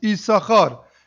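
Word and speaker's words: Yisachar (יששכר): We know that Yisachar is written Yud Sin Sin Chaf Reish, but technically it's only pronounced Yisachar.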